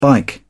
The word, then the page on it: bike: British English